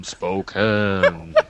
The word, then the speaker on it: Spokane: She's a housewife from Spokane, Seattle. – Oh, you pronounced it right.